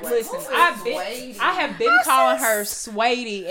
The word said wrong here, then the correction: Saweetie: and somebody at work was like, "I hate how she pronounces her name." I was like, "Yeah, Sweetie," and nobody's correcting. Oh, shit. Saweetie.